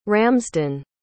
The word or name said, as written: ramsden